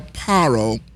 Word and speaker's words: Paro: The Most High says in Shemot, he says, go and tell Paro, which is Pharaoh, for all of those of you who don't know, we pronounce it Hebraically, mm-hmm.